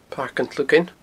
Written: Pairc an t-Slugain

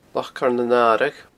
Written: Loch Coire nan Aradh

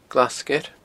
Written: Glas Sgeir

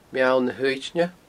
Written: Meall na h-Uaidne

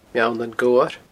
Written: Meall nan Gobhar

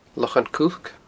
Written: Lochan Cuilc

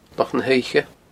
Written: Loch na h-Oidhche

